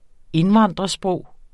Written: indvandrersprog

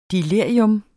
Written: delirium

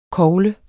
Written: kogle

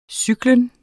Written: cyklus

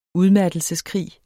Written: udmattelseskrig

